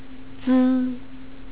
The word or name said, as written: zi